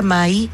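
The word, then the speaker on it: Mãe: Entonces, Mai es mamá en portugués. Se pronuncia Mai y se escribe Mae.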